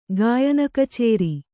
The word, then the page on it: ಗಾಯನಕಚೇರಿ: gāyana kacēri